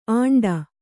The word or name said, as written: ಆಂಡ